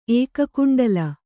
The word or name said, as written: ಏಕಕುಂಡಲ